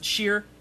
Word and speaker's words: shire: You know, I don't know the places that end in Shire, but you pronounce it Sheer.